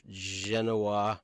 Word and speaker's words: Genoa: you're laying out a beautiful old slice of Genoa.